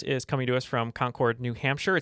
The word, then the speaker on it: Concord: I would be remiss if I didn't say uh, it is pronounced Concord, New Hampshire.